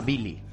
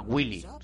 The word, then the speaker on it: Willy: Realmente, como se escribe, se llama Willy, se pronuncia así con W, pero realmente algo comentaban de que le llamaban Billy desde pequeño, no sé, una pájara.